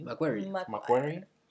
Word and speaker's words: McQuarrie: McQuarrie.